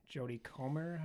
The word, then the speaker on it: Jodie Comer: Stars Matt Damon, Ben Affleck, Adam Driver, Jodie Comer.